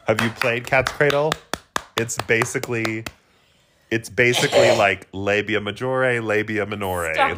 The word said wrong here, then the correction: labia majora: I love how you said it in Italian. – Wait, is that not how you say it? – Minore and majore. – Wait, how do you say it in English? – Labia major labia majora. – Majora, you were right.